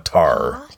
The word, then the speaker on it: Tar: And we learned that the Darksaber was created over 1,000 years ago by Tar Vizsla, the first Mandalorian Jedi. – Right? – Tar. T-A-R.